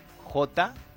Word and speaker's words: Jan: Jan en polaco se escribe con J, ¿sí? Jan, ¿sí? Porque Pero no suena la J. – la J suena como I en polaco, ¿no? – Ah.